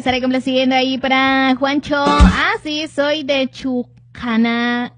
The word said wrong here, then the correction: Chucanán: Soy de Chucanán, le dicen Chucanán.